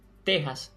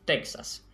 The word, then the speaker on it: Texas: Por tanto, también debemos decir Texas y no Texas. Es válido recordar que en estos casos es incorrecto, más que recordar, mejor insistir en que en estos casos es incorrecto pronunciar la X con ese sonido de KS que generalmente usamos para pronunciar la X en otras palabras.